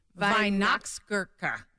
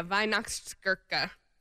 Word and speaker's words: Weihnachtsgurke: And it's called how do you say it? Weihnachtsgurke. – Yeah, Weihnachtsgurke.